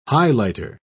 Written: highlighter

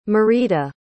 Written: Marita

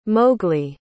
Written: mowgli

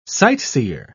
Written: sightseer

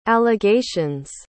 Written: allegation's

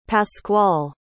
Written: pasqual

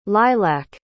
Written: lilach

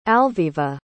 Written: alviva

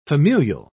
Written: familial

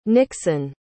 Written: nixen

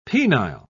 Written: penile

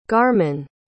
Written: garmann

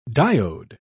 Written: diode

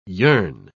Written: yearn

